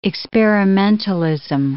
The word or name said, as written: experimentalism